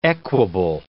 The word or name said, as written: equable